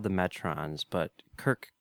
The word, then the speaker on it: Metrons: They call themselves the Metrons, note the short O. Kirk calls them the Metrones with a long O. One of your hosts called them the Metrions.